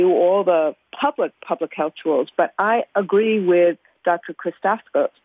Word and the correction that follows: Christakis: sorry if I mispronounced it, that Christakis, thank you.